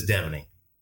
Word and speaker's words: Deveney: You know, everybody wants to say Deveney, but there's no A in there, so it's just Deveney.